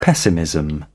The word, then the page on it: pessimism: British English